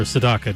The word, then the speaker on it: Sedaka: 1974, Neil Sedaka and Laughter in the Rain, or Sedaka, depending on how you pronounce it, there with Laughter in the Rain.